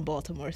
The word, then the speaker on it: Baltimore: Well, I don't say it like that, but that's how people from Baltimore say it. Baltimore. I say Baltimore. It's Baltimore. – Baltimore. – Yeah. Baltimore. Hey, shout. I'm from Baltimore. That's how they say it.